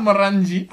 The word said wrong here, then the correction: meringue: How do you say it? – Meringue. – Oh, meringue. Oh, okay.